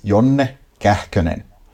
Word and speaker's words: Jonne Kähkönen: So the full name, first of all, is Jonne Kähkönen. That's right. How you pronounce it in Finnish.